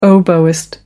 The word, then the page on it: oboist: American English